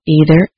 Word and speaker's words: either: There are two ways of pronouncing either and neither. People in both the United States and Britain may say either with E at the beginning, as in either, or with I at the beginning, as in either. Choose one pronunciation and stay with it.